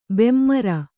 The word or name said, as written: ಬೆಮರ್